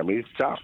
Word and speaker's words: Mircea: Realmente se pronuncia Mircea, pero bueno, aquí no vamos a dejar en Mircea Cărtărescu.